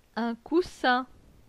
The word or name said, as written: Un coussin